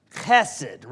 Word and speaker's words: chesed: There's a sound at the beginning, but it's chesed. That was better.